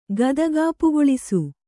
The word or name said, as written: ಗದಗಾಪುಗೊಳಿಸು